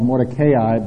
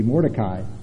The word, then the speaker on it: Mordecai: up until yesterday I called Mordecai, but one brother pointed out to me the proper Hebrew pronunciation would be Mordecai.